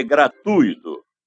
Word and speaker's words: gratuito: Como você pronuncia? Gratuito ou gratuito? O certo é gratuito, com U tônico. A sílaba tônica recai no U e não no I, e não leva acento gráfico.